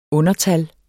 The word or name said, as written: undertal